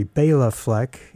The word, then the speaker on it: Bela Fleck: Bela Fleck and the Flecktones, sorry, Bela Fleck, as he likes to pronounce his name, and I want to honor that, Bela Fleck and the Flecktones from UFO Tofu.